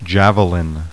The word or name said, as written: JAVELIN